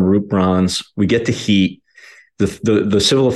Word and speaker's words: HIT: It was called HEAT, but it's spelled HIT.